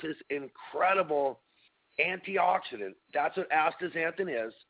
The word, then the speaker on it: astaxanthin: It's a pigment, and it's spelled A-S-T-A-X. So the astaxanthin, the X is pronounced like a Z, A-N-T-H-I-N. The xanthin just has an X instead of a Z. And if you remember that, it sounds like a Z, but it's really an X, then it's pretty easy to spell out and sound out astaxanthin.